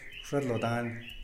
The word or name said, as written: wxadlodan